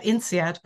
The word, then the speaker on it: INSEAD: And so that's what brought me to get my coaching certification at INSEAD, by the way, that's how you pronounce that.